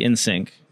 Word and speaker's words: Insynq: So could be in sync. – Sounds right.